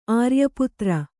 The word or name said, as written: ಆರ್ಯಪುತ್ರ